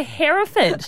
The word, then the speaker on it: Hereford: Then she went and said, is that a Hereford cow? I was like, that's not even how you say it. You say Hereford.